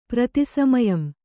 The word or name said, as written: ಪ್ರತಿಸಮಯಂ